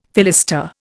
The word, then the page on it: fillister: Indian